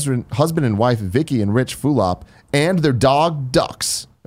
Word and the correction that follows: Dux: Oh, nope. It's pronounced Dukes. The dog Dukes, but it's spelled D-U-X.